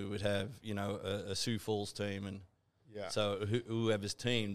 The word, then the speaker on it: Sioux Falls: And you just said, I was trying to figure out how to pronounce it because I was reading it, Sioux Falls. – Yeah, Sioux Falls. – S-I-O-U-X.